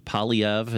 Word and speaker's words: Poilievre: P-O-I-L-I-E-V-R-E. Polyev is how you say it, but certainly doesn't look like that when you see the spelling. French of origin, I would imagine. P-O-I-L-I-E-V-R-E.